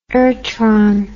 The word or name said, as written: irtron